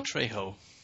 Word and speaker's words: Trejo: Trejo. – Because of the Mexicans saying their J's as H's.